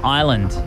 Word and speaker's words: island: Which letter is silent when pronouncing the word island? – S.